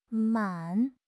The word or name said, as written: man3